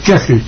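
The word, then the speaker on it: Köchel: Bueno, K-O con diéresis, C-H-E-L. – Pero se pronuncia como si fuera una E. – ¿Qué? Bueno, no exactamente Kegel, pero está bien, suena casi como... – ¿Cómo se pronuncia? – Sí, Kegel. – Eso. – Kegel, Kegel. Hay sonidos que no existen en español. – Es un sonido vocal entre O y E.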